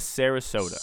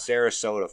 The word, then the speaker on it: Sarasota: We're going to Sarasota. – Sarasota, Florida.